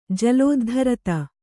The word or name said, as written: ಜಲೋದ್ಧರತ